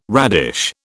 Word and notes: radish: British English

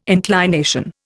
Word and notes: inclination: Indian

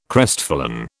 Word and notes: crestfallen: British English

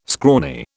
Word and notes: scrawny: British English

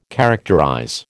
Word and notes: characterize: American English

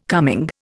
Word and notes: coming: Indian

